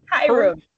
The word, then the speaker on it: Hiram: harem uh, gummer. This hey, harem. I, am I saying it wrong? Is it harem? – Hiram. – Hiram. Hiram.